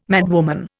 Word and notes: madwoman: Indian

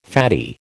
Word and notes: faddy: American English

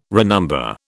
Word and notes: renumber: British English

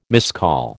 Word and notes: miscall: American English

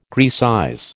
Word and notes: Grecize: American English

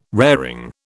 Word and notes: raring: British English

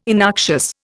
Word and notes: innoxious: Indian

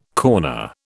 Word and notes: corner: British English

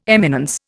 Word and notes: eminence: Indian

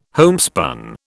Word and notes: homespun: British English

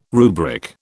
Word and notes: rubric: British English

